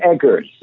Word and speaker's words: Eggers: I say Eggers. – Okay. – It's Eggers.